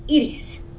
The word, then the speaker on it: Iris: And that's when I realized Spanish people pronounce Iris as Edie's. So I thought it was E-D-I-E, but really that's just how my mother pronounces I-R-I-S. So we were all referring to the same person, but us young people who are not originally from Puerto Rico and don't know fluent Spanish, don't know how you pronounce Edie's and that it sounds like Edie.